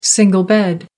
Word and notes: single bed: American English

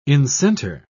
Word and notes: incenter: British English

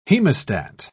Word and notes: hemostat: British English

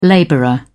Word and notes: labourer: British English

